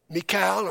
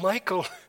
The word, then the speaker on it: Michal: Saul gave Michal, or some people pronounce it Michael, to David to be a snare to him.